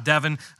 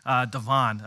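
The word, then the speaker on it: De'Von: Devon Achain. – Achain, yes. – Achain. So it's a hard ah in the beginning of Achain, and then also a hard on at the end. So not Devin, Devon.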